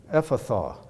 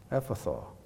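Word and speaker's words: Ephphatha: He said, Ephatha. That's how you pronounce that word. Ephatha. It's Aramaic.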